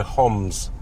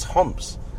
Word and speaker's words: Homs: Everyone says Palmyra. It's my two things I hate the most. Palmyra, and people go Homs. It's Homs.